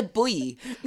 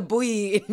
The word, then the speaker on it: buoy: How do you say it? You say it. – Buoy. – Exactly how I said it.